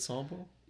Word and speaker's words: Sambo: Is it Sambo? – Yeah.